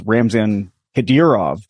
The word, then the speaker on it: Ramzan Kadyrov: Just speaking to the other half of the audience who may need a translation, it's Ramzan Kadyrov. – Thank you. – But you sure were close.